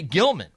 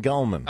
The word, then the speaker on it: Gelman: I just have this thing where I watched Regis and Kathy Lee, and he would always say Gilman, right? – Gelman.